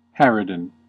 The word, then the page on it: harridan: American English